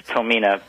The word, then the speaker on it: Tomine: Did I pronounce it right? Tomine? – It's close enough. It's Tomina, but you know, I've heard it so many ways that nothing's really right or wrong at this point.